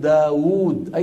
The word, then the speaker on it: داود: الواو مثل داود، أيضا هذه الواو ما هي موجودة، وورية مرت معنا منذ قليل، لا بد أن ننطقها ولو لم تكن موجودة في الخط.